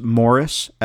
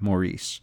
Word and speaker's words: Maurice: He is a British actor named Morris Evans. Spelling is the same, but the pronunciation is different. It's a British pronunciation of Morris instead of Maurice.